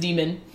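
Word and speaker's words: daemon: Yeah, so my favorite part of this universe is that everyone has a demon, which is spelled with, like, the A and the E connected to each other. – The Aeneid A-E. – So it's like, yeah, D-A-E-M-O-N-S, but the A and the E are connected. Apparently it's pronounced demon.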